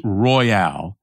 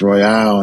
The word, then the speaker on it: Royal: It's spelled like Royal. R-O-Y-A-L, mm-hmm. but it's pronounced Royale.